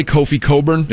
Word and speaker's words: Kofi Cockburn: Like the guy, remember the guy that played at Illinois, Kofi Coburn? – Yeah. – But it was spelled Kofi Cockburn.